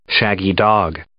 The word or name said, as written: shaggy-dog